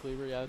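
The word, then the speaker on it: Kluever: Am I saying that right? – Todd Kluver, yeah.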